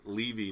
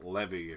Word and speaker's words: Levy: And for anyone wondering, yes, his last name is pronounced Levy, not Levy. If you want to see something pronounced Levy, come down to New Orleans, go down on the little hill that looks over water. That's a Levy.